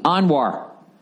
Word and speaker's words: Anwar: Sign up now to catch brand new stand-up specials like the breakout special from Fahim, pronounced Fahim Anwar, like Anwar Sadat.